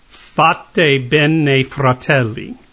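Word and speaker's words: Fatebenefratelli: Fateh Bene Fratelli.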